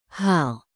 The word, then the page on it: hal: indonesian